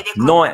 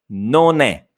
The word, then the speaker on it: non è: Avete sentito? Ha detto no è. Invece è non è. Vi ricordate? Non è. Bisogna pronunciare la seconda N e si unisce alla E. Quindi non è, non è, ok?